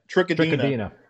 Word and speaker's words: Trichodina: And, the last one, I'm going to butcher this name, Trichodinia. Is that correct? – Yeah. Trichodina.